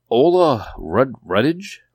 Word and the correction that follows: Olga Rudge: How did you butcher that name? – Olga Rudge.